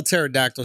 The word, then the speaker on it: pterodactyl: P-T-E-R. I know it starts P-T-E-R-O. – Oh, he's right. – I hate silent letters. What's the fucking point? You're not even saying pterodactyl.